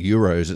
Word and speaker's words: gyros: They have, I believe it's pronounced gyros. It's spelled G-Y-R-O-S.